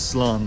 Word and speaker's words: slán: Or I could say goodbye, which is slán.